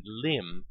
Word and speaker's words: limb: The silent B in thumb and limb is strange, since these words never had a B sound.